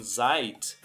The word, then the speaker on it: seid: E note que a pronúncia do in seid é som de T.